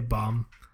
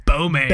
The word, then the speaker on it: Baume: I just say bomb. Because I say bomb and Mercier. I don't know. That's pretty standard. – I thought you were going to say Bome. It's Bome.